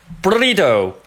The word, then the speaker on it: burrito: 然后下一个就是这个墨西哥的大煎饼，叫做 burrito，它的拼写是 burrito， burrito，但是我们会在这儿把这个 t 进行一个处理，变成一个跳舌音，变成 burrito。那我也经常被纠正啊，这个音的标准西班牙语发音应该是 burrito，我也不知道为什么，就是不用这种奇怪的声音就发不出这个声音嘞，就发不出这个这个感觉的。